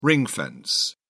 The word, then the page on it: ring fence: British English